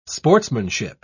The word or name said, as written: sportsmanship